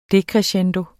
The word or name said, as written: decrescendo